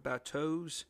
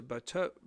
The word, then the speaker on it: bateaux: bateaux, and that's spelled B-A-T-E-A-U. Some people add an X at the end, a silent X.